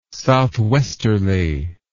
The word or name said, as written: southwesterly